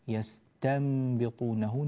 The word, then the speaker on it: يستنبطونه: كذلك ننبه إلى ترقيق التاء من قوله يستنبطونه، يستنبطونه نعطيها ترقيقها ولا نجعلها يستنبطونه بالتفخيم هكذا، فإن هذا خطأ.